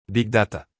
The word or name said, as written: Big Data